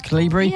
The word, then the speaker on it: Calibri: Is it Calibri? Pronounced Calibri? – Yeah.